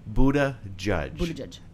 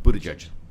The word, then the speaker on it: Buttigieg: And if you want to learn to say his name, it's Buttigieg. And then say it fast. Buttigieg.